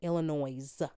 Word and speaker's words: Illinois: Illinois. I pronounce the S at the end just to piss people off. I know it's Illinois.